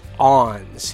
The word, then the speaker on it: awns: They've never even heard the term grass ons, and nope. – I'm pronouncing that ons, and it's spelled A-W-N-S.